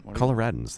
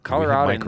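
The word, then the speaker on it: Coloradan: I think Coloradians or how do you say that? – Coloradans, Coloradans.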